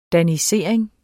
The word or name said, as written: danisering